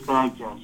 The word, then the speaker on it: Bagtaz: For some reason, when you put an A and a Z together, it really fucks people up, but it is Bagtaz, just like it's spelled.